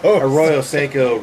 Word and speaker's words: Arroyo Seco: Arroyo Seco rhymes with ghetto.